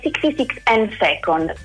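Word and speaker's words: 66thand2nd: Antoine Volodin è il nome dell'autore, la traduzione di Anna Delia, e l'editore è 66 and Second. E stavolta l'ho detto giusto, meno male.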